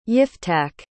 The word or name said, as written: yiftach